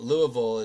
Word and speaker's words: Louisville: Louisville is how you pronounce it if you hail from that city.